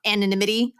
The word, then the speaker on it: anonymity: Kind of talking about anonymity. I said that word. Did I say that word wrong? – Yeah, you said it right.